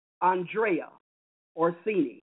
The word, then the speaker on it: Andrea Orsini: In that movie, Prince of Foxes, it's based on a gentleman named Andrea Orsini, and that's how you pronounce his name. It's an Italian name.